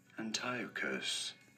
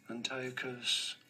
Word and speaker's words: Antiochus: There are two different ways of saying the first name, which are as Antiochus, Antiochus, or Antiochus, and then Epiphanes, Epiphanes.